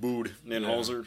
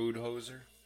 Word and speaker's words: Budenholzer: But Budenholzer, or however the hell you say his name, Buddy. – It's fine. We call him Coach Bud, but his name's actually pronounced Budenholzer. Yeah. – Budenholzer.